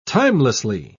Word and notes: timelessly: British English

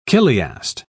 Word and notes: chiliast: British English

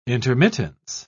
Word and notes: intermittence: British English

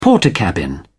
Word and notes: portakabin: British English

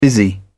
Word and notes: busy: British English

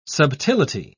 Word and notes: subtility: British English

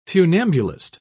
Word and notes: funambulist: British English

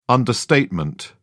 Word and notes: understatement: British English

